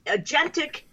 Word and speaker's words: agentic: And one of them is the term agentic. I don't even know if I'm pronouncing that correctly. – No, you did right.